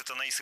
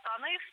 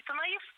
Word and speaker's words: Танаис: Да, это действительно первый город Танаис. Я буду говорить именно так. В принципе, можно и Танаис, это такая русская традиция произношения. Но если по-гречески, это всегда Танаис.